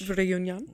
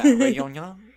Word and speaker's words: Réunion: So now some 16 months later, a team of beach cleaners on the coast of a French island called Réunion. I don't know how to say it in a French. – Réunion. – Yeah, Réunion.